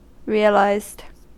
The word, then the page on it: realised: American English